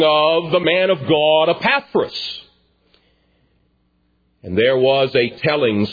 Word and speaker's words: Epaphras: I was humbled when I went to the pronunciation guide. I should have known it. It is Epaphras.